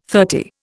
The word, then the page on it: thirty: Indian